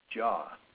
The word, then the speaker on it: Jia: How do you pronounce it? – Jaw. – Jaw. – Yeah. So pronunciation, I would say it was probably JAH. – Okay, but it's spelled Yeah. – J-I-A. – Yeah, it's Chinese.